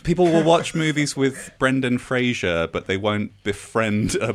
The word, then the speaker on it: Brendan Fraser: Anyone said his name correctly yet? – Frazier. Brendan Frazier.